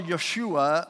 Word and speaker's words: Yeshua: And when he heard about this one they call Yeshua, and that's Jesus because there's no J in Hebrews. In the Hebrew language, you don't pronounce the J. So it's Yeshua or Jesus.